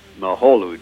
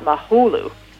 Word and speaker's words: Mahalo: Mahalo, doll. – That means thank you in Hawaiian, and of course, it's pronounced mahalo, not mahulu.